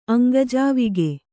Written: ಅಂಗಜಾವಿಗೆ

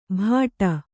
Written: ಭಾಟ

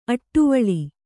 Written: ಅಡುವಳ್ಳ

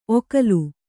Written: ಒಕಲು

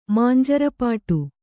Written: ಮಾಂಜರಪಾಟು

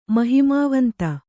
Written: ಮಹಿಮಾವಂತ

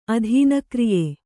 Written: ಅಧೀನಕ್ರಿಯೆ

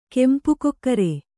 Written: ಕೆಂಪುಕೊಕ್ಕರೆ

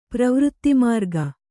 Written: ಪ್ರವೃತ್ತಿಮಾರ್ಗ

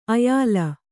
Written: ಆಯಾಲ

